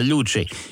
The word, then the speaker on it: Luce: Ça s'écrit Luce, mais c'est la Luce.